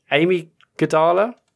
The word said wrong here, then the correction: amygdala: Amy Gidala? Is that how how do you say it, Sean? Oh, amygdala. Oh, is that okay? Even yeah, if, as in the bit of the brain.